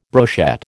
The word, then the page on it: brochette: American English